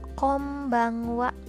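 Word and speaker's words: kombangwa (こんばんは): Kombangwa ditulisnya pakai n konbang konbanwa tapi dibacanya kombangwa.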